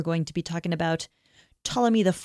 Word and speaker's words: Ptolemy: But there's another region centered in Egypt, and that was controlled by a general named Ptolemy. – Mm-hmm. – Actually, just Ptolemy. I don't know why. I don't know how a silent P can exist.